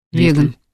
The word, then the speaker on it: веган: Веган. – Веган. Вот так ударение вернее ставить.